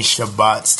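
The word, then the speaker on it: Shabbat: And we have shared many Shabbats together.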